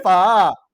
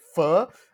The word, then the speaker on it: pho: It's a four, right? – No! Oh my God! Oh my God, it's not four! It's far. – A far. – Yeah. – It's a pho. So if you don't know what's a pho, it's a Vietnamese dish. – Yes, it's a Vietnamese beef noodle dish. It's called pho. Like how you pronounce F-U-R, like pho. Like that, pho.